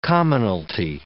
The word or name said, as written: commonalty